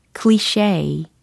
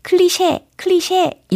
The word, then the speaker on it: cliché: C-L-I-C-H-E 라는 단어입니다. 요거는요, cliché, cliché, cliché. 이렇게 발음을 하시면 돼요. 클리셰, 클리셰 이렇게 이야기하면 원어민들이 잘 못 알아듣거든요.